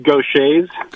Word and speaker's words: Gaucher: Okay, so the disease is called, it's pronounced Gaucher's.